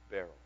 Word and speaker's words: Beryl: I'm sorry, let me pronounce it correctly. Beryl. B-E-R-Y-L.